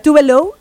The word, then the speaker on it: Tove Lo: O sea, se llama Tove Lo, o sea, Tove Lo es la pronunciación, pero su nombre real es Eva Tove Elsa Nilsson.